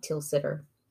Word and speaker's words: Tilsiter: It was originally, actually Havarti was previously called the Danish Tilsiter, T-I-L-S-I-T-E-R. Makes me think I'm saying like Tilsiter. – Just say it like that. – Tilsiter. Perfect. Every time I say it, it sounds weirder, but it's not.